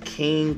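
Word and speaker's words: Kiin9: Um, if you guys want to check out my music, you could easily go to Google, type my name in, which is uh, pronounced King, but I spell it capital K I I N number nine P E R E Z Perez, or you could type in um, Crown Me King.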